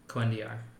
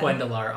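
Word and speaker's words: Quendiyar: Definitely not how I had it pronounced in my head. – I had Quendalar also. Anyway. – I thought they did the Y with the two L's. Quendiar. – They did. – They did. – Like, both of us. – But headcanon for us was Lar. – Quendiar. Exactly. Lar. – Yeah, this is the one time they've chosen to go with the French pronunciation instead of something else, which, okay.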